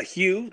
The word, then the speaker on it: Hugh: Hugh, just the way you'd spell Hugh, H-U-G-H.